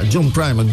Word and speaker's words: John Primer: John Primer, dan... Zeg ik dat goed? Ja, John Primer, Doyle Breblin de second, Kim Wilson en de Fabulous Thunderbirds.